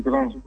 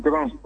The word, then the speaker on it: Gran: Monsieur Grand. – Monsieur? – Monsieur Grand. – Monsieur Grand. – Oui. – Ça s'écrit comment, s'il vous plaît? – G-R-A-N.